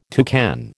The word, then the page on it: toucan: American English